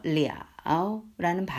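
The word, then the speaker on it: 리아오 (了): 이 러가 어, 다른 뜻으로 발음이 바뀌면서 리아오라는 발음이 붙게 되면 어, 뭐 완결도 되고 그리고 앞에 the 혹은 뿌가 붙어가지고 지금과 같은 상황이죠. 뿌가 붙으면 가능이나 불가능을 나타내는 뜻으로 바뀌어요. 예, 그러면서 발음도 바뀌어야 돼요.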